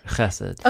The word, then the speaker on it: chesed: Chesed. – Okay, yes. Is it chesed or chesed? – Chesed. – The emphasis is on the first syllable.